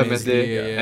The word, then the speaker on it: Meslier: Meslier? – Yeah, Meslier and Nick Pope are tied at 6.